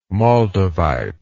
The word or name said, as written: moldavite